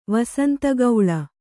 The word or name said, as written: ವಸಂತಗೌಳ